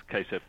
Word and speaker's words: KSEP: Am I pronouncing that correctly, the KSEP Technique? – Yes, KSEP, correct.